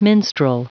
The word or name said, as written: minstrel